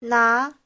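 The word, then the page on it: 拿: na2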